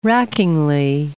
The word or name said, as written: rackingly